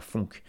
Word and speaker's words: phonk: Il disait, ouvrez les guillemets, alors pour un auditeur de Damso, je dirais que la funk, ça s'écrit P-H-O-N-K, je précise. Ah, petit aparté. P-H-O-N-K, donc la funk.